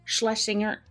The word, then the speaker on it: Schlesinger: This was directed by John Schles- John Schlesinger.